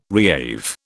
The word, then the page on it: reave: British English